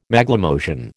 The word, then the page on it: Maglemosian: American English